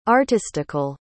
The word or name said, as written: artistical